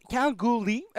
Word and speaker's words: Kalgoorlie: qui est une ville australienne qui se prononce Calgary sans le R qui précède le L-I-E à la fin, parce que le, le R n'est qu'un mensonge, it se comme on dit par là. ça? K-A-L-G-O-O-R-L-I-E.